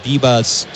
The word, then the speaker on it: Vivas: Jorbit Bibas, spelled J-O-R-B-I-T, and then Bibas is spelled V-I-V-A-S as the B sound.